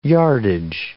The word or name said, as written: yardage